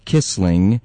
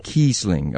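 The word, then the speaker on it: Kiesling: And it's Rebecca Kissling, by the way. – Oh, how did I say it? I'm sorry. Kissling. Okay.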